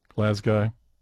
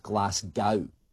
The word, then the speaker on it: Glasgow: It's surprising how many people think it's pronounced Glasgow. There is no cow involved. It's also not pronounced Glasgow. Basically, that first noise is not glass. How is it pronounced? Well, that's negotiable. So, the most common way you'll hear it pronounced is Glasgow.